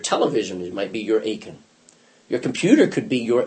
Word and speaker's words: Achan: You all remember chapter 7 is a guy named Achan, A-C-H-A-N. And Americans say it all the time. Oh, my aching back. – I know that. I've heard it before. – Oh, my aching back. It's just they pronounce the same.